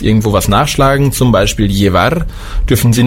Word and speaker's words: llevar: Das Doppel-L wird ausgesprochen wie LJ, beziehungsweise nur wie J. Llevar heißt bringen oder tragen. Auf dem Festland wird es sehr oft ausgesprochen wie LJ. Allerdings hier, dadurch, dass wir viel Beziehungen zu Südamerika haben, sagt man einfach nur J. Also llevar.